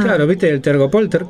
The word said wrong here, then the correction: telgopor: ¿Cómo se dice? – Tergopol. Telgopor.